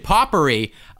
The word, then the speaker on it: potpourri: This next one, make scented potpourri. Oh, I love... – You Poppery. Still said it wrong. – He's still saying it wrong.